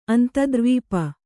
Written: ಅಂತದ್ರ್ವೀಪ

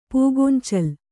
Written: ಪೂಗೊಂಚಲು

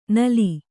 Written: ನುಲಿ

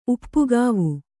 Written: ಉಪ್ಪುಗಾವು

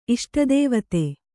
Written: ಇಷ್ಟದೇವತೆ

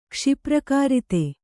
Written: ಕ್ಷಿಪ್ರಕಾರಿತೆ

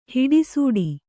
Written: ಹಿಡಿಸೂಡಿ

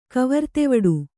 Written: ಕವರ್ತೆವಡೆ

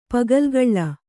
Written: ಪಗಲ್ಗಳ್ಳ

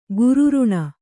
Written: ಕಿರ್ರನೆ

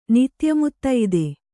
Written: ನಿತ್ಯಮುತ್ತೈದೆ

